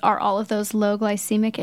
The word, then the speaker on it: glycemic: So it's a low glycemic index sugar. Did I say that right? – Yeah. – Low glycemic index sugar.